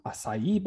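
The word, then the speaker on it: açaí: And then their sister fast casual chain, Pure Acai, is going to be in there for some acai bowls. Emphasis on nice. The E. Acai good pronunciation, yeah.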